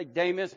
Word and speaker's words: Demas: I say Demas because it's actually the long E in the Greek. It's the eta there. Demas.